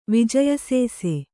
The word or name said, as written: ವಿಜಯಸೇಸೆ